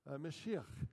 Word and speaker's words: Mashiach: Mashiach is how you roughly pronounce that in Hebrew.